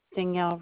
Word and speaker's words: Danielle: Danielle Rota. – Danielle, D-A-N-I-E-L-L-E? – Oh, correct.